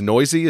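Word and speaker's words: Neuse: and I think it's Noisy, is that yes. How do you say it? Yeah, that's exactly right.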